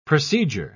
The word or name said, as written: procedure